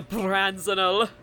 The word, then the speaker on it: Branzenol: Can you roll your R on the Branzenol? – Branzenol.